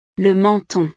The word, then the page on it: le menton: frances